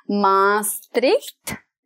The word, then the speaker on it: Maastricht: So I know that you were born and raised in the southern Dutch town of Maastricht.